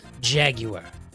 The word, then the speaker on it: Jaguar: Jaguar. Why do you pronounce the U? I don't know. It's just how they do it over there in jolly old England.